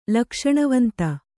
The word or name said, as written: ಲಕ್ಷಣವಂತ